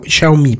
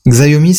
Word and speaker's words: Xiaomi: Juste pour, pour préciser, il y a Randall Flack qui nous dit dans les commentaires que ça se prononce Xiaomi, pardon. – Xiaomi, c'est Xiaomi Ouais.